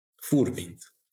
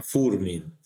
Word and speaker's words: Furmint: Furmint. So the accent is always on the first one, Furmint, yes.